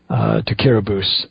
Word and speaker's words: Kiribati: It's spelled Kiribati, K-I-R-I-B-A-T-I, but it's pronounced Kiribati, because that island nation has 17% of its population is Latter-day Saint, and they have a high degree of malnutrition.